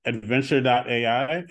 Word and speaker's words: Adventr: Well, our site, adventr.ai. Adventr is, again, adventr.ai. We couldn't afford the last U and E on Adventr, but it is pronounced that way.